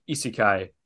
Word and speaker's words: isekai: It'd be like saying I don't like isekai. – Sure. – Did I get that right, Lucas? – Did I pronounce that correctly in the yes.